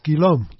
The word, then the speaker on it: Guillaume: It's G-U-I-L-L-A-U-M-E. – Pronounce Guillaume.